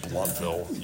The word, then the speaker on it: Blountville: Or is it when we first got here, I'm like, what's Blountville? And Roger's like, it's pronounced Bluntville, you Yankee idiot.